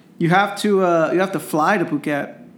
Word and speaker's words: Phuket: It's spelled like Phuket. So when I was growing up, people used to call it fuck it. But it's pronounced Phuket.